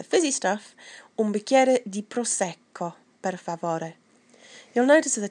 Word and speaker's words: prosecco: So when there's a double, like in prosecco, there's almost a little gap between the two C's. So it's prosecco, rosso. Any time that you see a double letter, make sure you pronounce both letters.